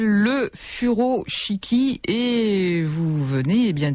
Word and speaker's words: furoshiki: Donc le furoshiki, hein, le U, on le prononce OU, c'est l'art japonais du pliage et du nouage du tissu.